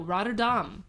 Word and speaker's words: Rotterdam: Rotterdam. – No, Rotterdam. – Rotterdam? – Dam rhymes with bomb. It is not dam as in dam.